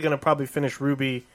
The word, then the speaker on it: RWBY: But I'm glad you didn't call it RWBY because I was gonna light your ass on fire. – Yeah, it's pronounced Ruby, but yeah.